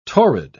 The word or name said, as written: torrid